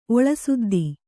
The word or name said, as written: ಒಳಸುದ್ದಿ